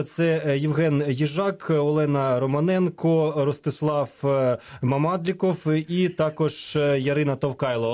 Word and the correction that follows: Мамадлойков: Одне прізвище. Чи правильно, чи неправильно? – Мамадлойков. – Мамадлойков. Перепрошую.